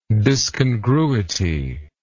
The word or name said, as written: discongruity